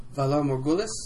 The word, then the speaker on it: Valar Morghulis: Hey, say it right for me. – Valar Morghulis? – There you go.